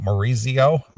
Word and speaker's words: Maurizio: Well, is that how you say it? Maurizio? – Maurizio.